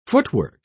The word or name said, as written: footwork